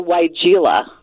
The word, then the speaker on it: weigela: This is a weigela. – It is. – So, yeah, all right. – Yeah. Before I knew how it was pronounced, I think I called it weigela or something like that.